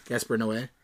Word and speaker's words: Noé: I don't know how to pronounce the E. – I think you said it right. – Gaspar Noé? – Yeah, I think so.